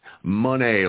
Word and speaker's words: Monet: Monet, like money.